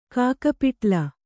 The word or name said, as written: ಕಾಕಪಿಟ್ಲ